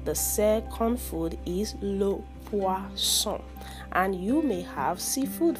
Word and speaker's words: poisson: The second food is le poisson.